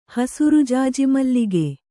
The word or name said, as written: ಹಸುರುಜಾಜಿಮಲ್ಲಿಗೆ